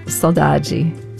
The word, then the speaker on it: saudade: The linguists say that of the world's 7,000 languages, only two are exact cognates, and that's Hirayth, and I have the Brazilian pronunciation, saudade.